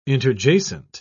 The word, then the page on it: interjacent: British English